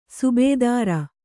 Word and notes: ಸುಬೇದಾರ: subēdāra

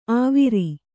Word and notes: ಆವಿರಿ: āviri

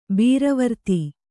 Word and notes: ಬರಾವರ್ದು: barāvardu

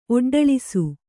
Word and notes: ಒಡ್ಡಳಿಸು: Kannada

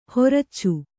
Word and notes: ಹೊರಚ್ಚು: horaccu

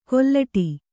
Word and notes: ಕೊಲ್ಲಟಿ: kollaṭi

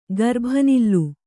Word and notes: ಗರ್ಭನಿಲ್ಲು: Kannada

